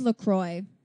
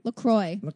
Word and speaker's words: LaCroix: Wait, it is LaCroix. It rhymes with enjoy. – Oh. – LaCroix. That is how you pronounce it.